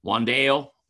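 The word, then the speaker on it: Wan'Dale: Wandale.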